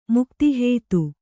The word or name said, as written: ಮುಕ್ತಿಹೇತು